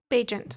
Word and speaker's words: Bagent: you say his name Bagent, I said? – Bagent. – Bagent.